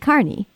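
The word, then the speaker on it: Kearney: And Carney is spelled, for the edification of listeners who might not know, might not be from that area, it's K-E-A-R-N-E-Y, but it's pronounced Carney. – Yes, like a carnival, exactly.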